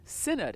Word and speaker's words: synod: First of all, what is a synod, and am I pronouncing the word correctly? – You are pronouncing it correctly.